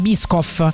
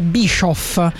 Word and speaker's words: Biscoff: Cioè, si pronuncia Biscoff. Invece io, tranquillissima, è una vita che li chiamo Bischoff. E mi sono resa conto che un po' la mia generazione li chiama Bischoff, quindi vedo un H dove non c'è.